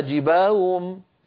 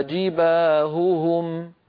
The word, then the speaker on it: جباههم: لا يصح هذا أبدا، بل لابد لنا من بيان صوت الهاء الأولى من صوت الهاء الثانية، وأن ننطق الهاء صافية ليست مشوبة بصوت آخر حلقي، هكذا. فتكوى بها جباههم جباههم، ببيان صوت الهاء.